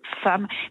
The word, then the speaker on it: femme: C'est le même processus, ça vient du latin femina, F-E-M-I-N-A, qui progressivement donc, s'est prononcé en France femme, puis femme. Mais là encore, les lexicographes ont tenu à maintenir le lien avec l'origine latine, donc le premier E, même s'il se prononce A.